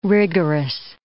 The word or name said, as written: rigorous